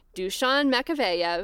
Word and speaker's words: Dušan Makavejev: But Dušan Makavejev.